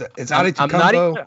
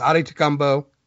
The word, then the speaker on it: Adetokunbo: It's Adetokunbo.